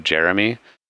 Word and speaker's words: Jarame: So I recently met a gentleman by the name of Jeremy, but it's spelled J-A-R-A-M-E. – No. – And so every time I look at it, all I can think is Jerame.